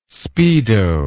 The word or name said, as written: speedo